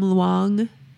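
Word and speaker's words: Tham Luang: Was that two syllables or three? Tom Luang? – It's two words. – Okay. – Tom. – Tom. – T-H-A-M.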